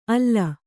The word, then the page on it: ಆಲ್: Kannada